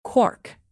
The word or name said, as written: quark